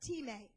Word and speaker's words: time: The New Testament word in Greek is time. It's pronounced time, which basically means value or price paid.